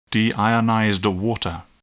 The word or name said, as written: deionized water